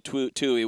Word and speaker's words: Twohy: T-W-O-H-Y. – I would like to say Twohy. – I believe it's Tui. – I've been saying Twohy, and then told me Tui, which I'm sure it sounds more correct.